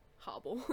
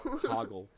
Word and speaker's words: Hoggle: Hobble. – Hoggle.